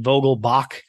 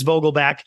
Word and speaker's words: Vogelbach: I think someone pronounced it Vogelbach, and which I'm sure, you know, many generations ago, that was the correct pronunciation, but it's Vogelbach.